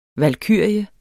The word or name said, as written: valkyrie